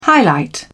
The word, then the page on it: highlight: British English